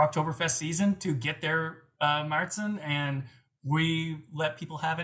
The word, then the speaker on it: Märzen: And then we actually do a year-round Märzen, which you might know that more as an Oktoberfest, but we call it a Märzen, or Märzen is the correct German pronunciation.